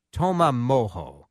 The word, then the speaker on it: Toma Mojo: So we're glad to have a couple of the team in charge of Toma Mojo Grill. I said it right. – Yeah, you said it right. – It's spelled T-O-M-A and then M-O-J-O.